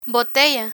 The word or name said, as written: botella